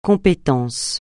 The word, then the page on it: compétence: francais